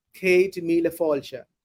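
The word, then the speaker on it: Céad míle fáilte: Cade Mila Falcha. – Cade Mila Falcha.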